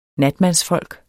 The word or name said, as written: natmandsfolk